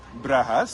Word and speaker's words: Brugge: So it's B-R-U-G-G-E-S in Dutch? – One G. – Oh, don't be stupid. It's one. – B-R-U-G-E-S. – In English. – Okay, what is it in Dutch? – Okay. – Brugge, so B-R-U-G-G-E. Two G's, you don't pronounce any.